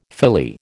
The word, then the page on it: Philly: American English